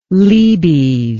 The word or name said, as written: lebes